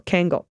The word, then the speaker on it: Kangal: pronounced K and Angle, Kangal, a breed similar to but distinct from the Anatolian.